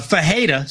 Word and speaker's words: Ferreira: However, when it comes to somebody like Bruno Ferreira, we make an H sound for the R.